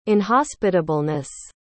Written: inhospitableness

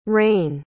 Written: Rayne

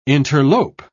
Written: interlope

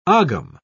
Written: ogham

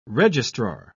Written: registrar

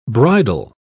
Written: bridal

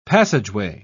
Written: passageway

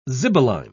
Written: zibeline

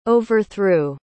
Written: overthrew

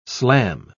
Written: slam

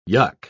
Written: yuk

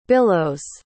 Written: billows

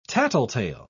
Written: tattletale

